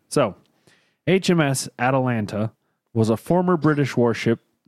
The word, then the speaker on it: Atalanta: Yes, it's spelled exactly like Atlanta with an A in between the T and the L. And Atalanta.